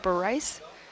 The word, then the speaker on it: Bareiss: Occupy the Arts is having an opening tonight from 6 to 9 at the, how do you pronounce this? B-A-R-E-I-S-S. Bareiss? – Sounds right.